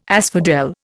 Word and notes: asphodel: Indian

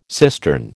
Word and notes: cistern: American English

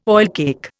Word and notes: oilcake: Indian

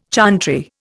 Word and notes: chantry: Indian

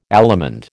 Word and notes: allemande: American English

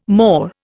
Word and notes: maw: Indian

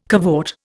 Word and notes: cavort: Indian

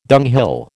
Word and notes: dunghill: American English

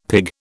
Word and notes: pig: British English

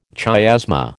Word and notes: chiasma: American English

